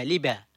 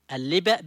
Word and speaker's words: اللبأ: واللبا في لهجة مأرب تنطق بتسهيل همزة القطع في آخر الكلمة لبا، وتسمية اللبا بهذا جاءت عربية فصحى جاءت من الفعل لبأ. قال أهل اللغة: اللبأ بهمزة آخره هو أول اللبن عند الولادة قبل أن يرق، ولبأ اللبأ طبخه.